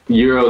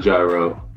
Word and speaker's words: gyro: 3-2-1. We need the build up. We need the countdown from 3-2-1. – Euro is probably first. I hate when people mispronounce it. It's not a gyro, it's a euro.